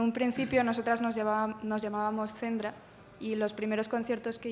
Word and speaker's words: Cendra: Lo pronunciamos Cendra, es que al principio poníamos la cedilla.